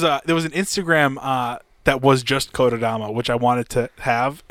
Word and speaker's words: Kotodama: So Kotodama, pretty much as it sound, analog.